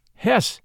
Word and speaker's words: Hesse: By the way, I did check the pronunciation of the name Hesse, H-E-S-S-E, which is pronounced Hesse in English and Hesse in German.